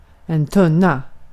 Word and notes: tunna: /ˈtɵ̀na/

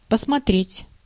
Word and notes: regarder: /ʁə.ɡaʁ.de/

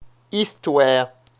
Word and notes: histoire: [l‿i.stwaːʁ]; France (Paris)

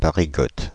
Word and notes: Parigote: [pa.ʁi.ɡɔt]

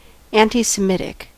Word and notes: anti-Semitic: /ˌæntɑɪsəˈmɪtɪk/

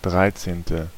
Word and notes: treizième: /tʁɛ.zjɛm/; France

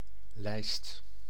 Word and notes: lijst: [lɛjst]

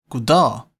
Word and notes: good day: American English; /ɡʊd deɪ/

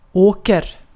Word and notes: åker: /oːker/

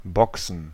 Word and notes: Boxen: /ˈbɔk.sn̩/

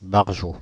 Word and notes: bekloppt: /bəˈklɔpt/

